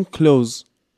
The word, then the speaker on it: clothes: ولی توی تلفظش ما نمیگیم clothes میگیم کلوز اینم به خاطر نزدیک بودن آواهاست نزدیک بودن این دوتا صدا یکیشون رو حذف